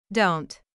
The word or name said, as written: don't